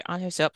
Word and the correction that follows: Hyo-seop: Kills hope. – and Hyo-seop, thank you.